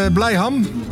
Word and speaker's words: Blijham: Daan is onze vaste fan uit Blijham. Zeg ik het goed? Blijham? Ja, Daan wil graag gaan voor het liedje van Robert Pater vandaag.